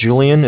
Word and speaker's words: Julian: Okay, Haley, my name's Julian. It's J-U-L-I-A-N.